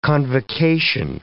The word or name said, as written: convocation